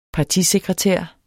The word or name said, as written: partisekretær